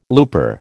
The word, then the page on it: looper: American English